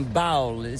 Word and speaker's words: Bowl: Je suppose que c'est comme un bowl. Se prononce comme bowl. Mais c'est bowl. – Bowl, mais c'est pas comme un bol. – C'est B-O-W-L.